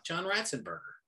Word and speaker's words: Ratzenberger: Yeah, if you remember the actor that played Cliff, John Ratzenberger, I believe that's how you pronounce it. – Yeah, John Ratzenberger.